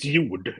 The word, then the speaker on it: Jord: Well, it's close enough. It's Jord if you want to talk Swedish, but I mean, I don't expect anyone saying Jord.